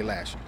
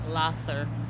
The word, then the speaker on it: Lahser: Lasher, not Lasser? – No, Lasher. – Even though it's spelled L-A-H-S-E-R? – Yes. – Lasser. – Oh, really? – Yes. – So it's not Lasher? – No, it's Lasser. – My nana live on this street. – How do you say it? – Lasher. – Some people pronounce it Lasher. – What way's the right way? – Lasser.